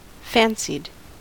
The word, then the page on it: fancied: American English